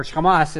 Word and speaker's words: Hamas: Because I get that it's actually C-H-A-M-A-S, Hamas. Or Hamas is how it's said in the Hebrew, but I was like really just kind of going, no, that's not the same thing.